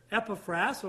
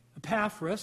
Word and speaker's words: Epaphras: Sixth man we see here is a guy named Epaphras, or Epaphras, some pronounce it that way.